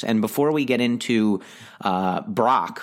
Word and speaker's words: Brach: It's spelled B-R-A-C-H, but I believe it is pronounced Brach.